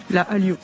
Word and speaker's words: Hallyu: Je prononce bien ? – La Hallyu. – Hallyu, voilà.